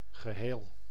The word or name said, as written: ensemble